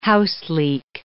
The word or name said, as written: houseleek